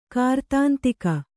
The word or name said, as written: ಕೃತಾಂತಕ